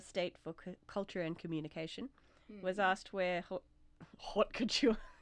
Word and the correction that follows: haute couture: I was doing so well with all my pronunciation and then I stuff up haute couture. He was asked where haute couture fit within French culture.